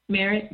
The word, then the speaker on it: Merritt: Our last name is spelled M-E-R-R-I-T-T, Merritt by Merritt.